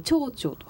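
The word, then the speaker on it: てふてふ: 昔のテフテフというふうに書いて蝶々と発音したそうです。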